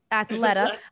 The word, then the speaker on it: Athleta: How do you say that? Athleta? Athleta? Yeah. – Athletica. – No. – No, Snook. Athleta.